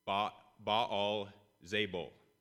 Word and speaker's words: Baal Zebul: But the ancient Philistines would have actually pronounced it closer to how it is in our text, Baal Zebul, which literally means Baal, their god, is prince.